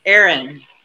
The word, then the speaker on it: Erin: Erin. – You say kind of like me, Erin.